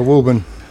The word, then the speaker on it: Woburn: Woburn.